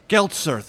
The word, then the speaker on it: Geltzer: Geltzer, thank you. Rhymes with seltzer.